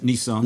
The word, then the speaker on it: Nisan: Is Nissan is how you say it? – Nissan. – Nissan. – Yeah. Mm-hmm. Okay. Almost sounds like the car, but it smells a little different.